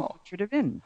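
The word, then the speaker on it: Pontrhydyfen: He was born in Pontrhydyfyn. And he actually told me that I pronounced it correctly.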